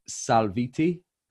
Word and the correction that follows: Salvieri: It's Italian, so it's Salvieri. – Salvieri.